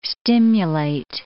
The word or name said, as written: stimulate